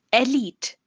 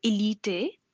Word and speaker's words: elite: Elite. Not elite or elite, it's elite.